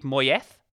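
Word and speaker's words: Moyes: Let me uh, uh, let me just get this pronunciation right. David Moyeth, I think is how you say it. that's it. That's it. That's, yeah, that's the Basque pronunciation.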